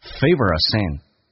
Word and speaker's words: favorite: 那么 favorite 这个单词跟我们想象的有点不一样，在美语当中少了一个 a，同时后面的 a 弱化成 a 这个发音，变成 favorite scent， my favorite scent，我最喜欢的味道。